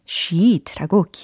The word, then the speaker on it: sheet: ice 다음에 sheet라고 길게 발음을 해주셔야 돼요. s-h-e-e-t 이렇게 철자가 어, 적혀져 있죠.